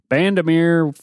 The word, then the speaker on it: Bandimere: They will be at Brandemere or Bandimere. I can't ever say that right. Bandimere for the Mile High Nationals in Denver, Colorado.